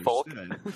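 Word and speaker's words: Fulk: It's F-U-L-K, but it could easily be misunderstood. Folk. – Folk Lake. No, it's pronounced Folk.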